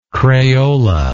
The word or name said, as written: crayola